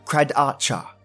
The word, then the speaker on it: Kradatye: and The Kradacha, spelt K-R-A-D-A-T-Y-E, but pronounced Kradacha, a true Australian Aboriginal skinwalker story by Illogical Musings.